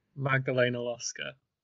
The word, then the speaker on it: Magdalena Loska: Magdalena Loska.